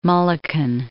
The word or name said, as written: Mulliken